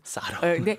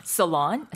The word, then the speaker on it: salon: 옛날에 사롱이라고. – 사롱. – 네, salon. 사롱이라고 알고 계시는 어르신들도 많이 계실 것 같아서 말씀드리는데요. 이거 sure. – salon이라고 발음하죠.